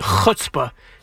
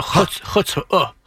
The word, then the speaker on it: chutzpah: It's actually a ch sound, chutzpah. So how you get around not saying a ch sound, that's up to each person. – Yeah, for sure. But he made it like a short U. I thought it was more like a long U or almost like an O. He said huh. – No, chutzpah. – Yeah, I'm saying almost like hoof, chutzpah. – Chutzpah. – There you go.